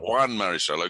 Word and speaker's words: Juan Marichal: Carlton Fisk, Catfish Hunter, and Juan Marichal. You're going to have to tell me if that's the right pronunciation. – Juan Marichal.